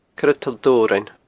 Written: Coire Toll Dobhrain

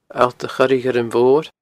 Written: Allt a Choire Ghuirm Mhoir

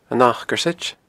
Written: Acarsaid

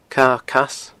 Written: Cadha Cas

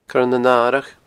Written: coirenanaradh

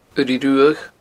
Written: uirigh ruadh

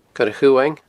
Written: coireachumhaing

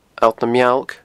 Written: Allt na Mealg

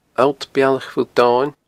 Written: Allt Bealach Phuill Domhainn

